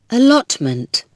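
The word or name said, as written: allotment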